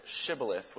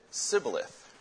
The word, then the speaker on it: shibboleth: And the reason why they did that is because the Gileadites pronounced it shibboleth with an S-H. But the Ephraimites pronounced it sibboleth. They couldn't pronounce the H in the word.